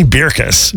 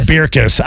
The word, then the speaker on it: Beerkus: It's definitely Bierkus. B-E-E-R-K-U-S. – Okay, I got it. – If that's not Bierkus, I don't know Bierkus.